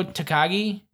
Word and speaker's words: Takagi: L.I.J., which is Bushi and Shingo Takagi, or is that how you pronounce his last name? Shingo Takagi? – Yeah.